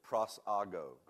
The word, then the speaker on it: prosago: It's in the top, and it's the word is pronounced just like it's spelled, and the word is prosago. Can you say that? Prosago.